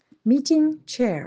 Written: meeting chair

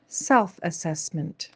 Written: self-assesmnt

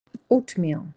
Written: oatmeal

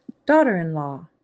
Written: daughter-in-law

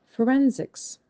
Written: Forensics

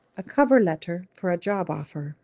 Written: A cover letter for a job offer

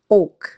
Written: oak

